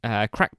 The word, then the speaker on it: craic: So I listened to an album this week, which I'll get onto in a minute, by Crack Boy Mental. Crack is spelled CRAIC. – Irish.